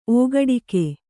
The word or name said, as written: ಓಗಡಿಕೆ